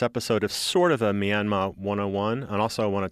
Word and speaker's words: Myanmar: Am I pronouncing that right? I don't think I am. – Pretty close. In the language of the country, they dropped the R at the end, so it's more Myanmar.